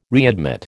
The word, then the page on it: readmit: American English